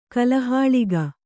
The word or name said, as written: ಕಲಹಾಳಿಗ